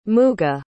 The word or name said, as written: muga